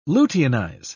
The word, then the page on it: luteinize: British English